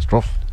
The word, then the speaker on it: Struff: Struff.